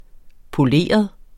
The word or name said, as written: poleret